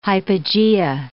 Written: hypogea

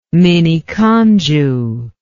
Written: miniconjou

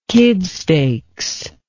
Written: kid stakes

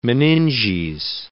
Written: meninges

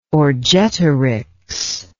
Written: Orgetorix

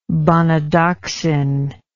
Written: bonadoxin

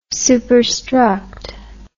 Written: superstruct

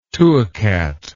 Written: two old cat

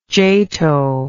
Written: jato

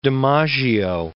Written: DiMaggio

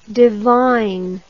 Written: devine

